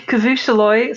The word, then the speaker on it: Khivooshalloy: The deck is called Khivooshalloy, Spawn of Aerodent. I typically call this deck Khivoosh because Khivooshalloy is ridiculous.